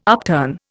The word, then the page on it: upturn: Indian